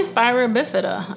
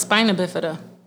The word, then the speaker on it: spina bifida: What's a spira bifida? I thought it was spina bifida. – Yeah, and she said it wrong.